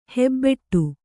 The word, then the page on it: ಹೆಬ್ಬಟೆ: Kannada